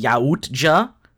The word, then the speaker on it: Yautja: And uh, this week we uh, we are moving away from the uh, the Yautja uh, which is how it's pronounced, I learned today.